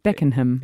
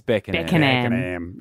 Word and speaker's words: Beckenham: It's all about the pronunciation too. – Yeah. – Beckenham. – It's Beckenham.